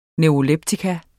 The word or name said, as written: neuroleptikum